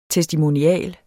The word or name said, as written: testimonial